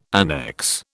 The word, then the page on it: annex: British English